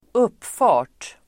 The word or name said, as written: uppfart